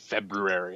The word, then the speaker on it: February: I remember as an adult man realizing that I've been saying February wrong. I was watching an episode of The Tonight Show with Conan O'Brien, and he very intentionally pronounced it February.